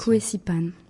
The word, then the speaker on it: Kuesipan: Kuesipan.